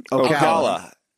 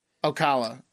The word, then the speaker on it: Ocala: Ocala. – Ocala.